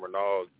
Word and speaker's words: Renald: My name is Ronald. I'm definitely a new caller. – Ronald. How you spell that? – R-E-N-A-L-D. It's spelled like Reynolds, but it's pronounced Ronald.